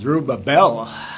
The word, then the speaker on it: Zerubbabel: And we do note that according to Strong's pronunciation, it's Zerubbabel with the accent on the last.